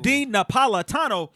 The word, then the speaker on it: Dean Napolitano: We have Mr. Dean Napolitano. – Yeah. – Let's give him a round of applause. Dean Napolitano. Dean Napolitano.